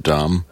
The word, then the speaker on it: Dame: South Bend, where Notre Dame is. Yes. I pronounce it Dom.